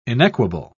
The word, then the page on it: inequable: British English